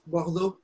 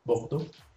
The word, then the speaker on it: Bordeaux: Y, ¿cómo se pronuncia en francés? – Bordeaux. – Bordeaux. – Bordeaux. Burdeos, la R esta. Me tardé como 10 años.